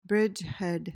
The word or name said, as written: bridgehead